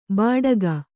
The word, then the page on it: ಬಾಡಗ: bāḍaga